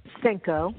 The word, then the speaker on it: Senko: Senko.